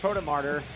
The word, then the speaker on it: Protomartyr: We're at St. Stephen Protomartyr.